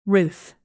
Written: ruth

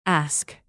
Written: ask

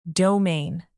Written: domain

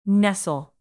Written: nestle

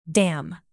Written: dam

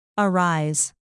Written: arise

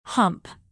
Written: hump

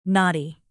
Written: knotty

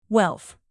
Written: wealth

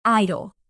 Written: idol

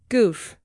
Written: goof

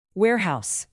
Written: warehouse

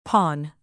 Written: pawn

